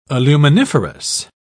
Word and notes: aluminiferous: British English

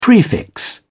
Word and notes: prefix: British English

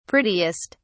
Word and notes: prettiest: British English

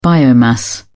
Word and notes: biomass: British English

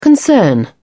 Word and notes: concern: British English

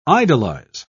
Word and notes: idolise: British English

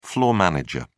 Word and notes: floor manager: British English

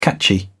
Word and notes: catchy: British English

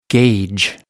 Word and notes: gage: American English

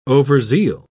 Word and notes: overzeal: British English

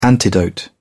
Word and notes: antidote: British English

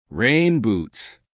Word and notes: rain boots: American English

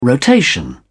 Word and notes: rotation: British English

